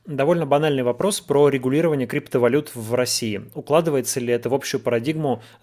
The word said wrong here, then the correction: укладывается: А, извините, так э, неправильно поставил ударение. Укладывается ли в общую парадигму закручивания гаек запрет оборота вообще под светлым знаменем борьбы с чем-либо?